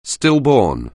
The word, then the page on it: stillborn: anglais